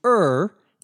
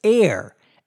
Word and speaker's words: err: The expression to err, E-R-R, on the side of caution, C-A-U-T-I-O-N, means to be very careful, to not take any risks. I should mention that that word, E-R-R, is now often pronounced somewhat mistakenly, but now more commonly as air, as though it were spelled A-I-R. In fact, it's so common that it's probably considered by most people an acceptable pronunciation in American English. In British English, however, it is always pronounced er.